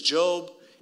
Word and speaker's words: Job: It's spelled Job. It's not. His name is Job.